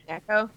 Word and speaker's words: Neko: I had some prior, like, experience with Nico Case. Sorry, I keep trying to pronounce it the other way around. – Neko? Yeah. – Neko, it's... Oh.